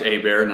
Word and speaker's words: Hebert: So as you said, I'm Josh Hebert, not Hebert or Herbert. But I appreciate you pronouncing that correctly.